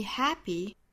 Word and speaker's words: happy: You make me happy, happy 里面这个 p 的发音呢是送气打折，大家不要发成 happy，不是 p，而是很近似于我们汉语里面阿波茨的这个波这个音 happy, happy，美音是更明显的 happy, happy。